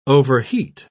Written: overheat

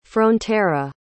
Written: frontera

